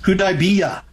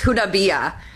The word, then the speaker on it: Hudaybiyah: Hudaybiyah. – Yes, Hudaybiyah.